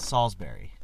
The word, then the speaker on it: Salisbury: Dude, I go back and forth between Bury and Barry. I feel like I say words weird sometimes. – I've always said Salisbury. – Yeah. – You know, if I'm saying the Bury, obviously you say the Bury. You don't say the Barry.